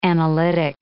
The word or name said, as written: analytic